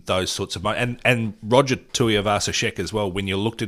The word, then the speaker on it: Tuivasa-Sheck: Roger Tuivasa-Sheck. Did I pronounce that correctly? – You did, yes.